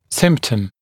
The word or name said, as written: symptom